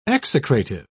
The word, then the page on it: execrative: British English